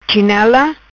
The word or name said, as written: Chinela